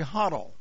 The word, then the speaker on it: Hottel: It was written by a man by the name of Gary Hottel. I believe that's the way you pronounce his name, H-O-T-T-E-L.